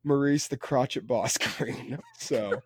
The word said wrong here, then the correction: crochet: Yeah, I believe it was crochet, is how you pronounce that.